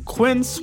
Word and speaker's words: Quince: Today Explained's support today comes from Quince, which rhymes with since but is spelt with a Q-U.